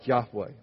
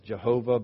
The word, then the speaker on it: Yahweh: Yahweh, often mistranslated by us in English as Jehovah, but it's undoubtedly wasn't ever pronounced that way, probably Yahweh.